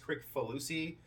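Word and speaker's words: Kricfalusi: I mean, John, his last name probably gets massacred a lot, so I think they call him John K because it's pronounced Crick Falusi. – Yeah, Chris Falusi. – I've always heard Chris Falusi.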